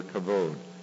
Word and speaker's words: kavod: In the word Ichabod, it is the last part, and the B is pronounced like a V, or kavod.